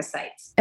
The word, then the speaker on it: Sites: Everything is Nurse Jessica Sites. – And that's spelled S-I-T-E-S.